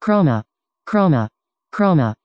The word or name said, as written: chroma